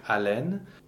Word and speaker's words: haleine: So the word for uh, breath, haleine, the H is not pronounced. There is no sound that goes with an H in French. It's a silent consonant.